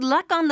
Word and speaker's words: luck on: Good luck on the project! – 皆さん。この good luck、これなんですが、 good luck から good luck。この感じです。クはもちろん発音しなくてもいい場合もありますが、今回はオンが続きますので、 luck on となりますね。